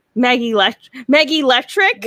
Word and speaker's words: Meggie Lectric: We're here with, if I butcher this, I'm sorry, Meggie Lectric.